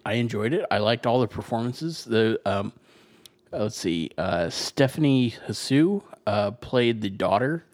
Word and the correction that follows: Hsu: It's actually pronounced Shu.